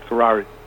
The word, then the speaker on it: Sahrawi: And they drove out much of the native population, people known as Sahrawi, which is spelled two different ways, but it's basically Sahrawi.